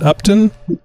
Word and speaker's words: Upton: Is it Upton? – Yep, you got it.